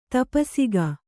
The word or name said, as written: ತಪಸಿಗ